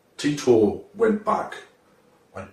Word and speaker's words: Titor: His name was Titor. – I think you'll find it's pronounced Titor.